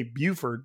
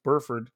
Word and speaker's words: Burford: I want to say Buford, but it's not. It's Burford. Big boy, big offensive lineman, 6'4", 304 pounds.